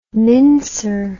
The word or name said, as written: mincer